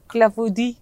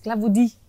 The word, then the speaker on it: clafoutis: Clafoutis. – Ja, men faktiskt, clafoutis.